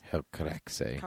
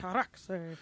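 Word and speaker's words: Helcaraxë: Hel Caraxe. – Caraxe. – It's H-E-L-C-A-R-A-X-E with the two dots.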